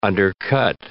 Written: undercut

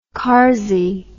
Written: karsy

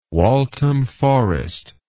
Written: Waltham Forest

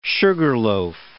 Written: sugarloaf

